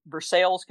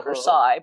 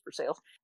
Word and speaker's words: Versailles: And it was black tie, so I put on my long black formal wear and spangly top and headed off to Versailles, Kentucky, which is how we pronounce it. It looks like Versailles, but it's Versailles.